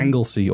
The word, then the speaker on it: Anglesey: Anglesey or Ynys Mon is the Welsh.